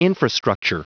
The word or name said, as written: infrastructure